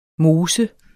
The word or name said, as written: Moses